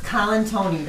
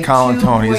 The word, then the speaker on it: Colantuoni: It's Colantuoni. That's how you pronounce it. Like Colantuoni. Two boys' names, Colantuoni.